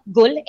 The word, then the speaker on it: Gul: So my Instagram handle is Natalia Gul, N-A-T-A-L-I-A-G-U-L.